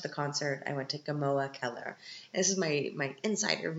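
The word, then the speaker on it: Gmoa Keller: If you want to go to a typical Viennese restaurant where all the Viennese go to, you have to go to Gamoa Keller. And Gamoa is spelled G-M-O-A. Gamoa Keller.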